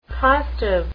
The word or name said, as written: costive